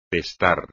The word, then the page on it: testar: espagnol